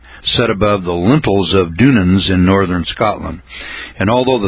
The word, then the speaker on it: Dunans: It's spelled D-U-N-A-N-S.